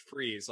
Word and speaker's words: Fries: So the little slogan I use is spelled hot, but you say it cold. So in other words, it's spelled like fries, like French fries, but it's pronounced Freeze, like Mr. Freeze.